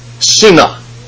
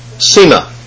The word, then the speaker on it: seneh: Let me spell it for you. Here's the Hebrew. S-E-N-E-H. Sina. Sina.